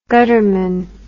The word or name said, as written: gutterman